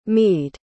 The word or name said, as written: meade